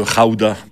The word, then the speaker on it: Gouda: Le pays d'où vient le fromage, le gouda ou le rauda, parce qu'ils prononcent les G comme des R dans ce pays, c'est les Pays-Bas.